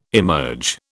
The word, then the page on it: emerge: British English